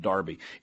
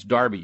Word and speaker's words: Derby: This email I read with great interest from Keith in Darby, and he told me to make sure that I pronounced it Darby. It is correct. It's Darby, even though it's pronounced, or even though it's spelled Derby, it's pronounced Darby.